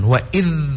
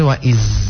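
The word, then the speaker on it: wa idh (وَإِذْ): Ah, ini tahan wa idh. Jangan sampai wa iz. Itu huruf zai. Ilo balanga de zai itu ila pala de zai. Wa idh huruf za.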